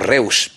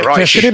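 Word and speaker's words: Reusch: Y decíamos Reus, pero es que queda ahora más. – Decir Roche. Se escribe Reus, efectivamente.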